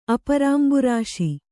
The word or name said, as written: ಅಪರಾಂಬುರಾಶಿ